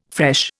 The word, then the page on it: fresh: Indian